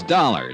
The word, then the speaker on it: Dollar: It's Dollar, Johnny Dollar. – Dollar, huh? – Yeah, rhymes with collar.